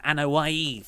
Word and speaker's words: Anoa'i: You're referring to the Anoa'i family. – That's right, yes. – I just said that because I know how to pronounce it.